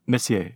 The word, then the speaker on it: Messier: We have an entire image of these winds in the galaxy M33, or is that pronounced Messier 33? – Messier, yeah. French dude.